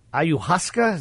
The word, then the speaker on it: ayahuasca: Aaron Rodgers did ayahuasca.